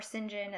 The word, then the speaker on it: St. John: And then St. John, or St. John, as they always pronounce it, he kind of tries to, like, court her and stuff.